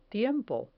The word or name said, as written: tiempo